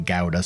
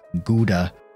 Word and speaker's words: Gouda: Some said, I think it was mainly Germans, and I think some English pronounce it Gouda. Some say Gouda. I was going to go for the proper pronunciation of Netherlands, Gouda.